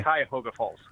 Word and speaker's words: Cuyahoga Falls: Is that the correct pronunciation? How did I do? – Yeah, that's correct. – Really? Cuyahoga Falls? – Yep. – Okay. – Cuyahoga Falls.